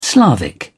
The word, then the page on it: slavic: British English